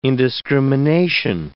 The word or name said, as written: indiscrimination